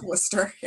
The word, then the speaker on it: Worcester: There's a silent R in the middle of Worcester.